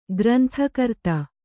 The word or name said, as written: ಗ್ರಂಥಕರ್ತ